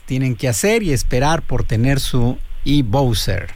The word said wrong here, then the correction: e-Boxer: E-Bowser, ¿no? Así se pronuncia. – E-Boxer. – Boxer. – E-Boxer. Boxer. Bueno.